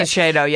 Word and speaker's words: Shiseido: Shiseido, yeah.